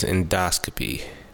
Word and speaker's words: endoscopy: The proper pronunciation of the word is endoscopy.